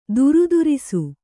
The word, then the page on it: ಥರಥರಿಸು: Kannada